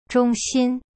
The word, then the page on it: 中心: zhong xin